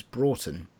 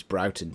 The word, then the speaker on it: Broughton: Um, I would highly recommend a short book about making films by the poet and poetic filmmaker James Broughton. Although that could be James Broughton, depending on the